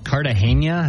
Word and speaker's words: Cartagena: Cartagena? Is that how you say it? – Yeah.